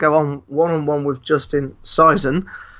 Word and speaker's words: Sysun: His name is spelt S-Y-S-U-N, so Sizen.